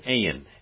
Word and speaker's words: ayin: And then we have the word ayin. And that's, it would be like E-Y-N, actually, is a little bit better pronunciation. Ayin. Ayin.